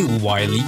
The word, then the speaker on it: Wily: et Wily, qu'il faut le prononcer, enfin.